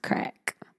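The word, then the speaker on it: craic: It's spelled. It's pronounced crack.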